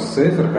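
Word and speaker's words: Сефер (ספר): Всегда ладно, потому что Сефер, конечно, ударение на первом слоге.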